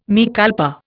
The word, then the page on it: mea culpa: Indian